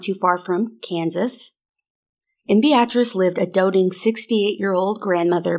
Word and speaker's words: Beatrice: You mean Beatrice? – No. I watched the docu-series, and I've been pronouncing it as Beatrice. It's Beatrice. At least that's what they said.